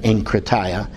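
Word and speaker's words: enkrataia (ἐγκράτεια): You who are Greek students know that that's the pronunciation. Really, it should be E-G, but we pronounce that enkrataia.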